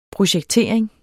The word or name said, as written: projektering